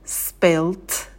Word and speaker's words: spilt: «Spill», «spilt», «spilt». Звук «п» не придыхаем, потому что он идет после «с». И второй и третий глаголы мы в конце «т» произносим с придыханием «spilt», «spilt».